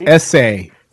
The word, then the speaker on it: Esai: It's pronounced Essay.